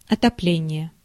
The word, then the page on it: heating: American English; ['hiː.tɪŋ]